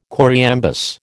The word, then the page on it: choriambus: American English